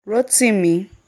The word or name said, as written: Rotimi